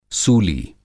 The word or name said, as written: सूली